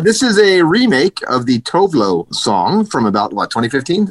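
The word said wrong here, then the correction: Tove Lo: Tuvelu. Oh, it's Tuvelu? – Tuvelu. – Oh, I said it wrong. My bad. – Yes. She doesn't I care. Apologize. As long as you buy her music, she doesn't care. But is she Sweden? Swedish, and that's why they pronounce it Tuvelu.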